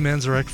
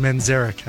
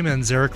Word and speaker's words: Manzarek: We will be back next week with a cover of, I'm sorry, with an episode dedicated to Ray Manzarek from the Doors, or Manzarek, I should say. Pronounce it correctly. Ray Manzarek from the Doors, a tribute to the keyboardist that we lost this week.